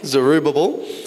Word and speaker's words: Zerubbabel: Zerubbabel,